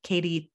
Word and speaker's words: Katy: And the church is called Grace Community Church and it's West in Katy, Texas.